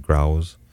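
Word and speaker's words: Grouws: Hey, let's listen to, here's Jenny Grouse, G-R-O-U-W-S. I believe I would pronounce it Grouse.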